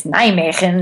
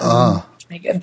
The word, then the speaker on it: Nijmegen: Actually, almost all of it was perfect, except Nijmegen is Nijmegen, not Nijmegen.